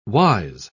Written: wise

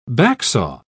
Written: backsaw

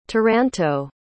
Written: taranto

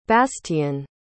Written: bastien

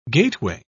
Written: gateway

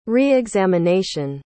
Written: re-examination

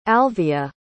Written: alyvia

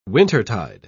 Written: wintertide

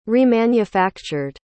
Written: remanufactured

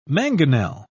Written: mangonel

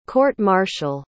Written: court-martial

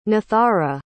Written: nathara

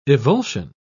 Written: evulsion

